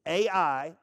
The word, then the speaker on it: Ai: It's A-I, but it's pronounced Ai.